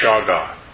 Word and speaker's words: Shagach: And I'm going to murder its pronunciation, but it's Shaga. S-H-A-G-A-C-H.